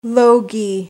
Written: logy

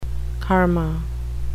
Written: karma

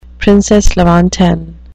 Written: princesse lointaine

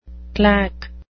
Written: claque

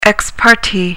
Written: ex parte

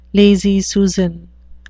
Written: lazy susan

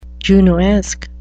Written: junoesque

